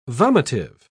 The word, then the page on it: vomitive: British English